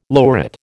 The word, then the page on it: lurid: American English